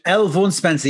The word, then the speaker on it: L. Vaughan Spencer: L. Vaughan Spencer, yes.